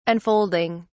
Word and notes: unfolding: British English

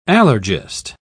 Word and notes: allergist: British English

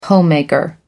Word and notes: homemaker: American English

